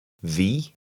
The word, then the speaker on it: vi: Vi. Vi. – The I in Norwegian is often pronounced like the double E in the English word C.